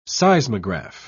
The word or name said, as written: seismograph